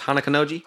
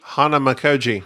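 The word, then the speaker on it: Hanamikoji: What's the game, card game that we always play that I always mispronounce? Hanakonoji? – Hanamakoji.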